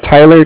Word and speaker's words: Tyler: My name is Tyler, T-Y-L-E-R. – Mm-hmm. – And I'm calling with Cardiff. It's spelled C-A-R-D-I-F-F as in Frank Brink.